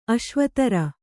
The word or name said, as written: ಅಶ್ವತರಿ